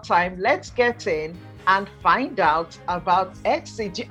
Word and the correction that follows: Asti: I'm Asti Rappaport, like the letters S and T. Just saying the letters S-T together, Rappaport. However you pronounce it, it's fine. I don't really care. – I've got it now. It's Asti.